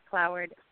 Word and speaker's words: Cloward: Kathryn is spelled K-A-T-H-R-Y-N. And then my last name is Cloward, C-L-O-W-A-R-D.